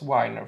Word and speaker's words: Weiner: Han uttalas Weiner förresten, för det är i.